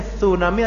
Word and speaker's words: tsunami: Contoh misalnya terjadi tsunami, ya, tsunami bacanya tsunami atau tsunami pak? Kalau kan TS kan berarti kalau TS di Arabnya tu, ya, sa, ya, lidahnya di antara dua gigi, ya. Tsunami, ya, tsunami kita orang kita tsunami, ya.